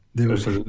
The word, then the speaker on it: Debussy: Sí, Debussy, Debussy.